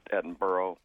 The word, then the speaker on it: Edinburgh: And I have in my possession a gavel that was carved from ostensibly a beam from, I believe, well, we would call it Edinburgh, but I believe the Scotch pronounce it Edinburgh Castle, was remodeled.